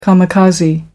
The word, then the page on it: kamikaze: American English